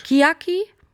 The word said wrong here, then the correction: Chiaki: Ganz falsch. – Chiaki? – Ja. – Ach, die Betonung.